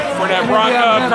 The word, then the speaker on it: Fernet Branca: And even like Fernet's brother, Fernet Blanco, or sister, I don't know how you say it. – Fernet Branca, Carpano, and Tinka.